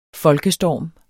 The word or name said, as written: folkestorm